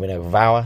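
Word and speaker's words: Rhinog Fawr: Um, and yeah, I mean, there's was Fort or, or Rinnog Vower, I think it's pronounced, Rhinog Rinnog um,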